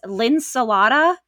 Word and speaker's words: Linsalata: Linsalata. Am I getting this right? – You say it just like you spell it, like Mojo Howitz.